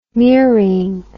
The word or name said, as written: mering